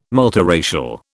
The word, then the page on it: multiracial: British English